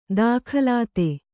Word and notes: ದಾಖಲಾತು: dākhalātu

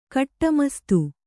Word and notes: ಕಟ್ಟುಮಸ್ತು: Kannada

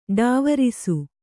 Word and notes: ಢಾವರಿಸು: ḍhāvarisu

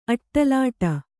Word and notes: ಅಟ್ಟಲಾಟ: kannada

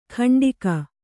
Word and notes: ಕಂಟಿಗ: Kannada

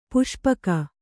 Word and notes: ಪಾಸುಪೊಕ್ಕು: pāsu pokku; Kannada

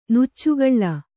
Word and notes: ನುಚ್ಚುಗೂಳು: nuccugūḷu

